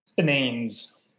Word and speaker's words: Spinanes: Is it the Spinanes? Is that how you say it? – Spinanes.